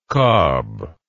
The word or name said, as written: kob